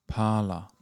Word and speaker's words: Parler: Parla. – Parla. – Yeah, it is said parla, but it's spelt parler, okay. like French, to yeah.